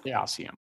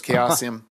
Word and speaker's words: Chaosium: And I'm going to go with how they pronounce it, which is Chaosium. – It was Chaosium.